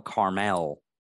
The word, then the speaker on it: Carmel: Here, Koresh competed for dominance with other leaders, Benjamin Rotten's son, George, until Koresh and his followers took over Mountain Camel. Is that camel or camel? I think it's camel. I'm saying this right every time. Oh, Carmel.